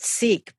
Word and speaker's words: Sikh: And Valarie Kaur is a Sikh who is a religion out of India, and that's spelled S-I-K-H. I've always called it Sikh, but I heard her call it Sikh religion.